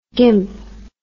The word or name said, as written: gymp